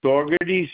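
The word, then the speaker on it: Saugerties: Dougherty's, New York.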